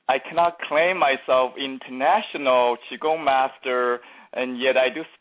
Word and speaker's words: qigong: Now, you really are the international expert in qigong, Gong. And for those of you listening, that's spelled Q I G O N G.